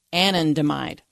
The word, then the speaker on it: anandamide: We've already gotten to I had to figure out how to pronounce it, but it's anandamide.